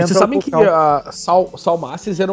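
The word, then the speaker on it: Salmacis: Salmasses, é assim que se pronuncia? – Sim.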